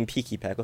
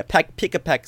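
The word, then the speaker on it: Pikipek: Okay, so the first one is Pika-Peck. Okay, I was thinking Peaky-Peck. Okay, yeah, Pika-Peck sounds better.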